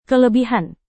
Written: kelebihan

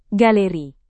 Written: galeri

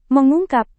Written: mengungkap

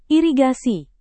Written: irigasi

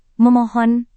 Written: memohon

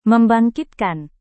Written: membangkitkan

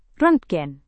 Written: rontgen

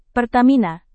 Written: Pertamina

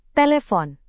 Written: telepon

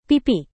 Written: pipi

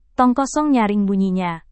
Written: tong kosong nyaring bunyinya

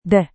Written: deh

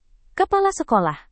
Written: kepala sekolah

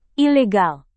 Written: ilegal

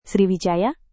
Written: sriwijaya

